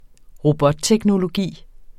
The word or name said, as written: robotteknologi